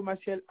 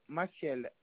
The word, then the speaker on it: Maciel: Monsieur Coelho Maciel Amiraldo. – Alors, le nom, ça s'écrit comment, monsieur? – C-O-E-L-H-O. – Le prénom? – C'est un nom composé. Coelho Maciel. M-A-C-I-E-L.